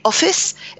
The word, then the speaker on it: office: Et office, euh, c'est un nom. Donc, top office. Et on met un accent sur la première syllabe de office. Tu me hmm. comprends oui. Office. Comme ça.